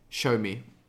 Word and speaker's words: Xiaomi: How do you pronounce the name of this company? It's Xiaomi, right? – Xiaomi. That company. Show me. We'll call it Show Me.